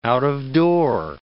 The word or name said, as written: out-of-door